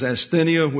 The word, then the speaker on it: asthenia: And so the word weakness is asthenia, excuse me, his middle syllable is accented. The word weakness is asthenia,